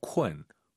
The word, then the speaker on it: 困: KUN인데 UN 사이에 어 들어가야 돼요. 쿤쿤 하지 마시고 쿤.